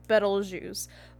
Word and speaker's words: Betelgeuse: So Beetlejuice's name is actually spelled like the star Betelgeuse, but apparently that a lot of people don't pronounce it Betelgeuse. A lot of normal people do pronounce it Beetlejuice. It's just astronomers would call it the correct name.